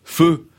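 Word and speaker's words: phở: Et l'une des spécialités de sa maison, Hanoï 1988, c'est le feu. Ça s'écrit faux, P-H-O, mais ça se prononce feu.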